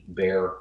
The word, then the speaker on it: Barre: His name is spelled B-A-R-R-E, Bear Lando, L-A-N-D-O.